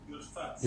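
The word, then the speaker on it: Yodfat (יודפת): The Chakra Shil Gush Chalav, and Chakra in the Gush Chalav area, the Yodfat, Hayashana, the ancient Yodfat, Yodfat or Yodefet, how do they pronounce it? Yodfat or Yodefet? – Yodfat. – Yodfat, right? So I don't know that's Yodfat, Yodvata, not Yodfata.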